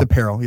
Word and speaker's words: APRL: Right? Yeah, pronounced apparel.